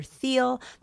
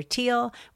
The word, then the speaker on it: Thiel: In the second segment, you will hear Mike refer to Palantir co-founder Peter Thiel as Peter Thiel. The right way to say it is Peter Thiel.